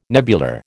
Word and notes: nebular: American English

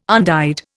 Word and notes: undyed: Indian

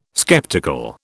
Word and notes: skeptical: British English

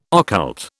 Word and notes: occult: British English

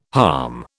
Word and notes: halm: British English

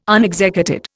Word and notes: unexecuted: Indian

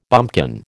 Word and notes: bumpkin: American English

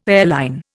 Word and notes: airline: Indian

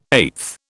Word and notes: eighth: British English

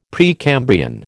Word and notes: Precambrian: American English

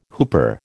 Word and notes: whooper: American English

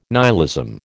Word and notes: nihilism: American English

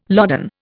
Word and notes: loden: Indian

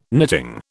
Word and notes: knitting: British English

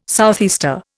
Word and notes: southeaster: Indian

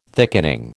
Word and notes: thickening: American English

